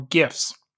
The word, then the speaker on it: GIF: People are play- paying an insane amount of money to own digital moments or gifts GIFs. – GIFs. – Shut up. – it's a GIF. Um, it's a hard G.